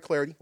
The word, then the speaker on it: Clardy: I'm on Twitter, at Troy Clardy. Last name is spelled C-L-A-R-D-Y.